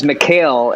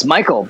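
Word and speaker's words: Michale: He would read it as Mikhail, and I was like, no, it's Michael, because obviously it's like Michael Graves.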